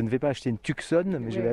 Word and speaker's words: Tucson: Ah, tout le monde dit ça, mais le C, c'est silencieux. Alors, s'il vous plaît, quand vous venez ici, il faut prononcer Tucson. – Tucson.